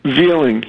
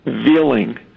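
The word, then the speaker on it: Veiling: His name is Larry Veeling. He's a firefighter. We never found him. His last name is spelled V-E-I-L-I-N-G. V-I-L-I-N-G, Veeling, and they've never pronounced it right.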